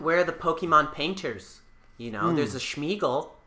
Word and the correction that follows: Smeargle: It's actually pronounced Smeargle, as in as in smearing a paintbrush.